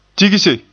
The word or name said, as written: tigse